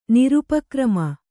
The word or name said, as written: ನಿರುಪಕ್ರಮ